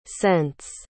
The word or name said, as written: cents